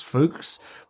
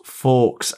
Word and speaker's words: Fawkes: Didn't see that the man behind the mask was the artist Francis Fuchs, but there again, they were pronouncing his name a bit odd. And when the reveal came, it was, of course, Fawkes, as in 5th of November.